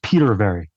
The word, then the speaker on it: Petervary: Yeah, just like it's spelled. It's Petervary.